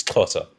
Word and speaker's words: Xhosa: The closest you could get to is for Xhosa. Like if you actually say it properly, it's Xhosa.